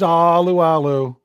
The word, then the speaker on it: Alualu: It's Alualu.